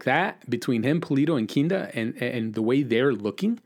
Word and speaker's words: Kinda: Gadi Kinda has got the funny name, Kinda, because it's spelled like kinda.